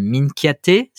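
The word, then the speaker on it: Minchiate: Tu es sur la, la finalisation, je crois, d'un nouveau système, puisque euh, il s'agit d'un tarot, euh, le Minkiate, si je le prononce bien.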